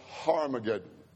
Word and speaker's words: Armageddon: In Hebrew, the Hebrew word for mountain is har. We would spell it H-R, har. And that's where they get the term R. It's really pronounced Harmageddon.